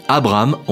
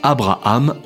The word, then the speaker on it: Abraham: Et oui, Abraham en deux syllabes et pas Abraham en trois syllabes, ça, ça viendra plus tard.